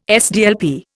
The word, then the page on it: SDLP: Indian